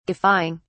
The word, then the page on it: guffawing: British English